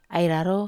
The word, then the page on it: ai raro: Wamesa